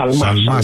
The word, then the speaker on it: Σαλμά: Σαλμά, συγγνώμη για το γιώτα που εμφυλοχώρησε στην προφορά μου.